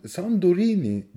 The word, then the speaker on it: Santorini: Evet Santorini San, Sandorini diye yazmışlar. Yazılışı mı Yunan, öyle? e, Yunanca'da N ile T harfleri yan yana geldiğinde D olarak okunuyordu o yüzden.